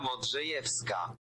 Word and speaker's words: Modrzejewska: Helena Modrzejewska. Helena Modrzejewska. – Modrzejewska. – Helena Modrzejewska. – Modrzejewska. – Oh, the W is a V.